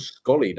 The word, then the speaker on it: Sxolie: So if any cider fans out there, get some Scully down you. Yes, yeah, so it's like trolley or dolly, but it's spelled S-X-O-L-I-E. Scully. It's got an X in it.